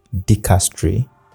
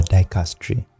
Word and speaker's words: dicastery: Your word is dicastery or dicastery, whichever way you want to pronounce it.